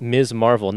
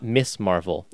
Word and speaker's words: Ms. Marvel: Pronounced Ms. Marvel, not Miss Marvel.